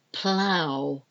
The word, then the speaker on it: plough: I'm pronouncing them hopefully clearly enough that you can hear and understand the O-U-G-H-T. Now we go on to O-U. And the sound for this is owl. It's not spelled O-W, it's spelled O-U-G-H, O-U-G-H. We've got bough, B-O-U-G-H, the bough of a tree. Plough, P-L-O-U-G-H, plough.